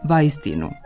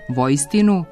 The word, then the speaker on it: vajstinu: Znači, na srpsko-slovenskom je vajstinu, na rusko-slovenskom vojstinu, a na srpskom uistinu.